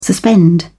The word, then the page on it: suspend: British English